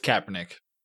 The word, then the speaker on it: Kaepernick: It, it, it's Kaepernick.